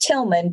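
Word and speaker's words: Tilghman: And for those of you that are listening, their name is it's pronounced Tillman, but it's T-I-L-G-H-M-A-N.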